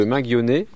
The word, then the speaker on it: Minguionnet: Donc les 19 et 20 septembre, donc nous sommes à Gourin, au manoir de Minguionnet. On le prononce comme ça, ou alors à la Bretonne.